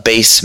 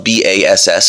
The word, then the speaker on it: Bass: Bass, Massachusetts. Uh, it's spelled B A S S, so I thought it was Bass, Massachusetts, and I thought Bass Mass was funny.